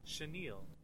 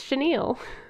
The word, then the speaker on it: chenille: How do you say this word? – Chenille. – Oh, it is. Chenille.